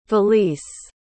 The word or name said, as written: valese